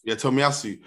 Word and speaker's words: Tomiyasu: Tomiyasu.